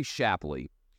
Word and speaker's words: Shapley: Now, Shipley has, in my mind, let me make sure I'm getting his name right, by the way, Gary Shapley has no, this is an IRS agent, Gary Shapley has no incentive to lie.